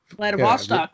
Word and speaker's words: Vladivostok: Vladivostok, yeah, very good.